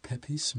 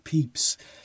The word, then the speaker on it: Pepys: Eh, het Londen van de dagboekschrijver Peeps. Samuel Pepys, indeed, yes. Met de juiste uitspraak, prima. Eh, want je schrijft het Peppies, maar mm. heel weinig mensen weten dat je het Peeps moet uitspreken.